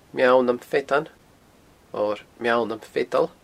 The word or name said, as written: Meall nam Feadan